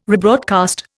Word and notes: rebroadcast: Indian

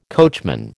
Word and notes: coachman: American English

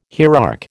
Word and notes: hierarch: American English

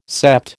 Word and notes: sept: American English

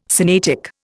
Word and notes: Sinaitic: Indian

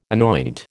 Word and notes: anoint: American English

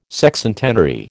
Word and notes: Sexcentenary: American English

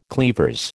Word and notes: cleavers: American English

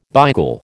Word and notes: beigel: American English

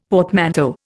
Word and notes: portmanteau: Indian

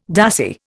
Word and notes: dassie: Indian

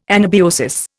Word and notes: anabiosis: Indian

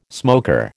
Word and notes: smoker: American English

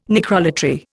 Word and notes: necrolatry: Indian

